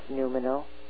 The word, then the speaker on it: noumenal: I think the word is pronounced noumenal. N-O-U-M... E-N-A-L, subtle and noumenal senses parallel to our physical ones to function in our multidimensional reality.